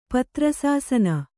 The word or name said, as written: ಪತ್ರಸಾಸನ